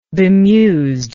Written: bemused

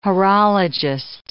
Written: horologist

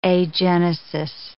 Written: agenesis

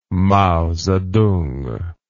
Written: mao zedong

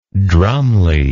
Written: drumly

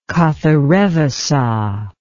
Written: katharevusa

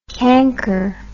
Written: cranker

